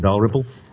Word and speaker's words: Dalrymple: Dalrymple.